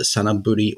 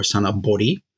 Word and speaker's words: Sanaburi: And as to how it's pronounced, both of them are attested. Looking at the records, it looks like sort of pre-19th century, Sanabori was more common.